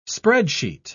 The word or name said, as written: spreadsheet